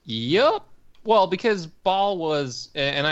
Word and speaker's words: Baal: The pet is called Baal, B-A-A-L, B-A-A-apostrophe-L. – Yeah. Alex would kill us if we don't point out Baal in Diablo is actually pronounced Baal, yada, yada, Baal. Yada. This is Baal.